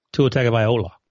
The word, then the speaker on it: Tua Tagovailoa: How do you say it? – Tua Tagovailoa.